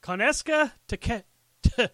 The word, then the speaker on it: Konosuke Takeshita: It's Konosuke Takeshita.